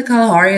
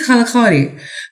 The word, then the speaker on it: Kalahari: Did you know the Kalahari is actually just like the easier pronunciation that like white folks came up with? It's actually Kalahari.